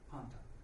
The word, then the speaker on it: panta: I didn't put this on the slide, but phonetically the word would be spelled P-A-N-T-A, panta, P-A-N-T-A.